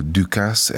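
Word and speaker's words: Dukas: 161 éve született Paul Ducasse, 1865-ben a bűvészinas szerzője, mindig vitta, hogy ő Ducasse, vagy Ducasse, hát görög felmenőkkel Ducasse-nak lenne helyes ejteni a BBC kiejtési kalauza szerint, de a franciák is Ducasse-nak mondják, úgyhogy maradjunk a Ducasse-nál.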